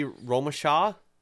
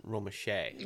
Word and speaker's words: Romesha: His name is Clinton LeVar Romache. – Ah, okay. – And it looks like it would be Romesha because it's R-O-M-E-S-H-A. – Yeah. – But he pronounces it Romache.